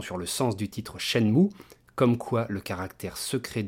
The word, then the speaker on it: Shenmue: espèce imaginée par Yu prononcer « Shenmue sûrement pour se rapprocher de la sonorité de Jianmu, alors que le kanji choisi par Yu Suzuki, différent du Hanze chinois de Jianmu, devrait se prononcer Sha-mu,